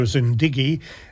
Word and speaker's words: Zindagi: And we should actually point out, I think, that although it's pronounced hello Zindigi, the spelling is Zindagi, isn't it? D-A-G, not D-I-G.